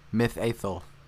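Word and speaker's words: Mitheithel: So anyway, on October 11th, three of the riders were guarding the last bridge of Mithithel. I might have pronounced that right. Who knows? – Mithathel.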